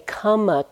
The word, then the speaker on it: kamma: And I often will say kamma, K-A-M-M-A, that's the Pali pronunciation and spelling of the word karma. I have to use my American accent, karma, K-A-R-M-A, is Sanskrit.